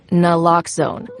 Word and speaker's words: Naloxone: For the record, it's Naloxone. Nuh, like, is Naloxone addictive? Nuh-uh. Lox, like Naloxone keeps humans safe, like locks keep bikes safe. And own, like Naloxone can save lives in our very own communities.